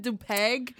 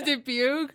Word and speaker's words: Dubuque: I pronounce it Dupeg instead, instead of Dubuque.